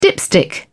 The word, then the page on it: dipstick: British English